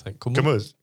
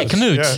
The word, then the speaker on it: Komoot: How do you say this? Komoot. Komoot. – Komoot. – Yeah, Komoot. Komoot. Yeah. Like Scott.